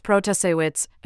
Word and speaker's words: Protasiewicz: In an interview at her home on Tuesday before the results were known, Judge Protasiewicz, pronounced Protasiewicz, attributed